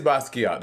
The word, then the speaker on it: Basquiat: I don't think, is it pronounced Basquiat? I think it's Basquiat. – Yeah, it is Basquiat.